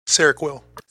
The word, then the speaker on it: Seroquel: Sorry if I'm pronouncing that word wrong. It's S E R O Q U E L. Seroquel. Thank you, Tyler. Man, what, perfect. Uh, you say Seroquel. Okay, Seroquel, antipsychotic.